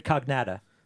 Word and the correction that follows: Cocagna: Oh, did I say that right? – Cocagna? – Cocagna, sorry. David Cocagna.